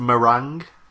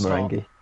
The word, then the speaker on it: Marenghi: I think it's pronounced meringue. – No, it's Marenghi. Okay. It's Garth Marenghi.